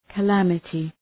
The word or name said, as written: calamity